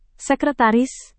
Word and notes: sekretaris: Indonesian

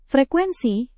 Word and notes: frekuensi: indonesian

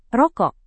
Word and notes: rokok: indonesian

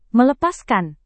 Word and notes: melepaskan: Indonesian

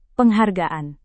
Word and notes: penghargaan: indonesian

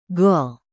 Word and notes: gol: Indonesian

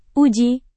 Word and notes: uji: indonesian

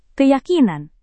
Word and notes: keyakinan: Indonesian

